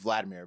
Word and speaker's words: Volodymyr: It's Vladimir, but it's spelled Volodymyr.